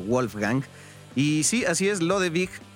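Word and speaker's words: Lodewijk: pero sí, la pronunciación, tal cual como tú lo decías, es Lodewig.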